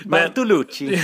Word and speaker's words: Bartolucci: Ett mål bakom eh, den fenomenale Cecena-spelaren eh, Mattia Bartolussi. men, eh, inte ba, ursäkta avslutet här på hans. Bartolucci. Bartolucci, absolut.